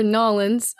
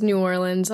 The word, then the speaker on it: New Orleans: If we're rolling the town and we didn't want to be viewed as an outsider, how would I pronounce New Orleans? – Yeah, well, Ryan said it perfectly before, Nolens, but realistically, probably just New Orleans. A lot of people like to say New Orleans, and that's kind of when you're like, oh, maybe they're a little bit, you're not from around here.